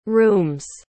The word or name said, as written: rooms